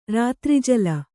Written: ರಾತ್ರಿಜಲ

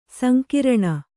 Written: ಸಂಗ್ರಾಣ

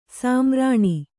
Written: ಸ್ಮರಣಿ

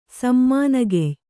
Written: ಸಮ್ಮಾನಗೆಯ್

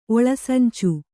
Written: ಒಳಸಂಚು

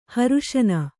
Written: ಹರುಷನ

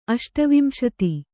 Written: ಅಷ್ಟವಿಂಶತಿ

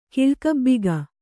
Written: ಕಿಳ್ಕಬ್ಬಿಗ

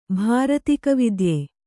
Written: ಭಾರತಿಕವಿದ್ಯೆ

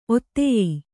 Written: ಒತ್ತಾಯ

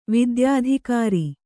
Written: ವಿದ್ಯಾಧಿಕಾರಿ

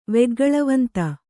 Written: ವೆಗ್ಗಳವಂತ

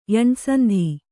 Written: ಯಣ್‍ಸಂಧಿ

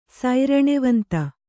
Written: ಸೈರಣೆವಂತ

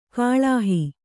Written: ಗಳುಹು

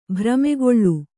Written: ಭ್ರಮೆಗೊಳ್ಳು

